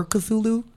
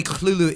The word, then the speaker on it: Cthulhu: So what do you, is it, do you prefer Cthulhu? Or, because I mean, technically Cthulhu is, it's canon. That's how H.P. Lovecraft wanted us to pronounce it.